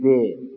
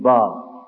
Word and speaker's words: با: ولی وقتی خواستیم تلفظ بکنیم نمیگیم جه، میگیم جیم. نمیگیم به، میگیم با. با اسم است برای همین این خطی دراز رو میرش نقطه است.